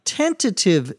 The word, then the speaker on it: tentative: Bruce says, we can come to some tentative decisions and make any adjustments later. A tentative, T-E-N-T-A-T-I-V-E, sometimes pronounced without the second T as tentative, means to be provisional, to be temporary.